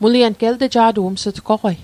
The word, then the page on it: elteja'toq: el'teja'toq